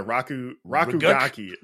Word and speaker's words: RKGK: Which apparently you pronounce it a Rakugaki. – Rakugaki.